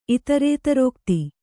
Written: ಇತರೇತರೋಕ್ತಿ

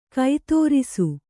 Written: ಕತ್ತರಿಸು

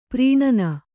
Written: ಪರಾನ್ನ

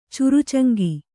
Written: ಚುರುಚಂಗಿ

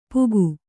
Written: ಪೂಕು

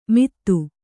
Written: ಮಿತ್ತು

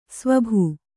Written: ಸ್ವಭು

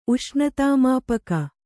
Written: ಉಷ್ಣತಾಮಾಪಕ

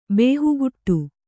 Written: ಬೇಹುಗುಟ್ಟು

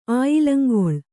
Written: ಆಯಿಲಂಗೊಳ್

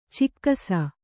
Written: ಚೆಕ್ಕಿಸು

